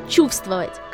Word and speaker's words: чувствовать: Тут же можно вспомнить слово «чувствовать», которое некоторые пишут как «чувствовать», только с двумя буквами «в», так как буква не произносится. Но вы же не пишете «солнце» без «л», хоть она также не произносится, верно? – На этом у нас пока все.